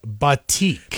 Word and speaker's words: batik: David on the talk and text line says it is pronounced batik.